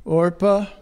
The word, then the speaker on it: Orpah: So the two daughters that they married was Oprah. Sorry, mispronounced that. Orpah.